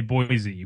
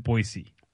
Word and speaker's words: Boise: Even though in the show they say Boise with the Z sound. But locals pronounce it Boise.